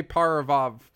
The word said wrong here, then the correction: Parajanov: We're done talking about Tarkovsky. – Sergei Parajanov? – Yes, you said it right. Sergei Parajanov.